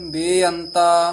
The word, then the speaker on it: बेअंत: ध्यान नाल उच्चारण करना है, पहला बेअंत जेड़ा है सियारी दे नाल है, इनू छेती बोल देना है, परे बेअंत। दूसरा बेअंता लां दे नाल है, इनू दीर्घ करके बोलना है, शुद्ध बोलना है, ये नहीं भी दोवे बेअंता बेअंता कह जाना है।